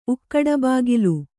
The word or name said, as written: ಉಕ್ಕಡಬಾಗಲು